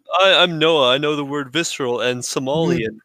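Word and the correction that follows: sommelier: Somalier. No, Somalier.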